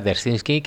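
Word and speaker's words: Dzerzhinsky (Дзержинский): la pronunciación es Dzerzhinsky,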